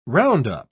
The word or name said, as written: roundup